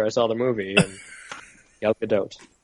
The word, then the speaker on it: Gadot: Oh, you said it right. – Not to split hairs, but he did not say it right. – That's how I've heard it's supposed to be pronounced. – Gal Gadot. I've heard her pronounce it. – Gadot. – Gadot. – Yes. – Oh, not dot dot.